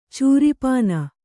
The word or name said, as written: ಚೂರಿಪಾನ